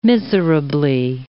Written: miserably

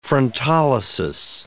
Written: frontolysis

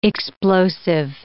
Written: explosive

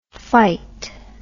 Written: phyte

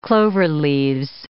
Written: cloverleaves

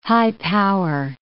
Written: high-power

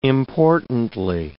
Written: importantly

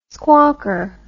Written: squawker

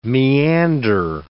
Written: meander

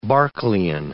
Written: Berkeleian

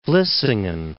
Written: Vlissingen

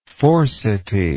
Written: Forseti

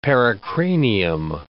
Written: pericranium

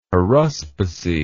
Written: haruspicy

